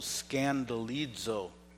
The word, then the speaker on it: skandalizo: It's a Greek word called skandalizo.